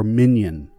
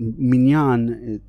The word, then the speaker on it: minyan: And it's called the minyan, right? Or minyan, as they're sometimes called in the Ashkenazi word. But minyan, you know, obviously is the Hebrew pronunciation.